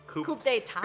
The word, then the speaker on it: coup d'état: She said it was a coup date. – Coup d'état.